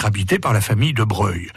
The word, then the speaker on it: Broglie: Et eh oui, parce que si on dit Broglie pour la commune, on prononce Breuil pour le nom de famille, car les habitants n'ont plus s'accoutumer à la prononciation italienne en usage dans le monde politique et aristocratique.